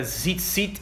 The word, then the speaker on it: tzitzit: To understand what a tzitzit is, and it's like T-Zit-Zit is how you would spell that, right?